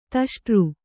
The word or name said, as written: ದಷ್ಟ